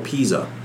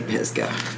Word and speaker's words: Pisgah: I wonder if they pronounce it Pisgah or Pisa. Because up here in Ohio, they pronounce it Pisa, and it's... – No, like, it's Pisgah.